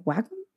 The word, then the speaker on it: Wacom: I was like, Wacom? – Wacom. Yeah, Wacom. Wacom's the correct way.